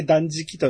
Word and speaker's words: 断食: これで断食と読みます。